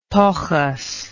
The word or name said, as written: tochis